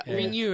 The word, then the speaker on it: Ringu: Ringo, yeah. – Ringu, I think. – When, you're right. Yeah. I'm sorry, I didn't pronounce it right for you. I got to pronounce it like it's Japanese.